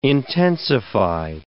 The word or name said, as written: intensify